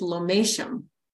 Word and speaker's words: Lomatium: Lomatium? Dissectum extract? Is that what LDM stands for? – So the way to pronounce it is lomatium.